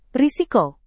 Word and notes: risiko: indonesian

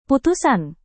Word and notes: putusan: Indonesian

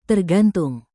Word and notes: tergantung: indonesian

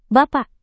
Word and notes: bapak: indonesian

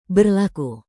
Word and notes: berlaku: Indonesian

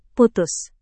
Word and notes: putus: Indonesian

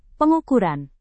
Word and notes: pengukuran: Indonesian

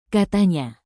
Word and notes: katanya: Indonesian

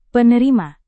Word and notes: penerima: Indonesian

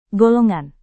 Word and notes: golongan: Indonesian

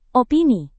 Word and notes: opini: Indonesian

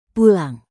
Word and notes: pulang: Indonesian

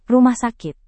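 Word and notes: rumah sakit: indonesian

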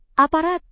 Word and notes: aparat: indonesian

